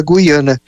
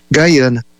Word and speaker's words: Guyana: Então, por isso que muita gente fala Guiana, mas, na verdade, é o aportuguesamento da palavra Guyana, que foi instituída em 1970.